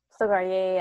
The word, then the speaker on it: Stilgar: Silkar or something like that. – Stilgar, yeah.